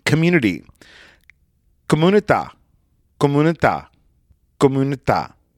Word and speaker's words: comunità: So it's spelled C-O-M-U-N-I-T-A. And the I has a little accent over it. So comunità. Now we say community. Community. Community. There's two M's. There's one M in Italian.